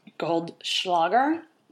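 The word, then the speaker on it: Goldschläger: So we are actually drinking Goldschlager. Did I say that right? – You did.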